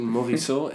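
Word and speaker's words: Morissot: This is my guess at their pronunciation. Morisseau and Sauvage. – That's very good.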